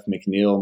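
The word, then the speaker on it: McNiell: It's Jeff McNeil. My last name is spelled funny. It's M-C-N-I-E-L-L.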